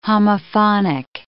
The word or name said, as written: homophonic